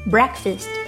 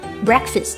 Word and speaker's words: breakfast: 你排多长时间的队了？Breakfast breakfast，早餐。早餐是一个复合词没错，但不要拆分成了 break 和 fast，应该读作 breakfast。